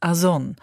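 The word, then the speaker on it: Ason (Озон): Ozon, russisch ausgesprochen Ason, tönt ähnlich wie Amazon und ist auch die russische Kopie des US-Online-Konzerns.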